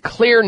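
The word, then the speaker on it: Xlear: That's spelled with an X, X-L-E-A-R. The X is for xylitol.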